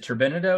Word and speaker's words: turbinado: Yeah, with some of the I might mispronounce this, but is it turbinado or turbinado sugar? – Turbinado.